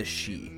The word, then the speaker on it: Sidh: the Shi, which is spelled S-I-D-H,